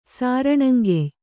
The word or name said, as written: ಸಾರಣಂಗೆಯ್